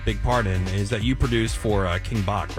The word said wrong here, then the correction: Bach: Yeah, King Batch. – He's so funny. – Is that how you say that? – Yeah. – I've been saying it wrong this entire time.